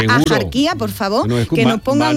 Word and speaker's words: Axarquía: Y aunque hoy la RAE deja claro, la RAE dice que lo culto y correcto es pronunciarlo con J, es decir, Axarquía, la tradición local sigue pronunciando y no solo escribiendo Axarquía o Axarquía, ¿no?